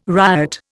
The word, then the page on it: riot: Indian